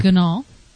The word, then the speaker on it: Ganahl: And Heidi Ganahl, is that how you pronounce her last name? – Ganahl.